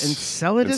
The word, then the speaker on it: Enceladus: Enceladus.